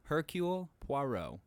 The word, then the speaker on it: Hercule Poirot: Hercule Poirot. – Poirot.